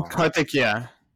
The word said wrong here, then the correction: Karthikeyan: There's only been two: Narain Karthikeyan.